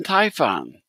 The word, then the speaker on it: Typhon: He also edited and published the critically acclaimed alternative comics anthologies Legal Actions Comics Volume 1 and 2 and Typhon. So let's see. How you doing, Danny? – Well, I'm so happy that you pronounced Typhon correctly.